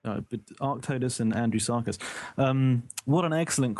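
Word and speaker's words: Arctodus: And he wants to know, what would win a fight between a large Arctotis? Am I saying that right? – Mm-hmm. Arctotis, the bear, yeah.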